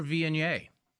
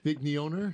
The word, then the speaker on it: Viognier: We're pouring a signature Viognier. And Viognier, you can try your best pronunciation of it, all of us who aren't French. – Vigneoner? – That's pretty close.